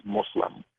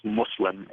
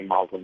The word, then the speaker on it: Muslim: I became so Americanized that I pronounce it Muslim, but really the true way of saying it in Arabic is Muslim. So whether you write it with a U or an O, it's Muslim is how it's pronounced.